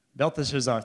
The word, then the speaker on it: Belteshazzar: Belteshazzar, how do you say it? Help me. Belteshazzar, thank you.